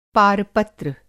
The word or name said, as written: पारपत्र